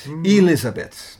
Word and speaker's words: Elizabeth: Elizabeth, met de klemtoon op de I.